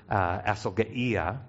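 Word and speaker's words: asalgeia (ἀσέλγεια): Greek word, asalgeia, right? Any Greek speakers there? Then I said it correctly.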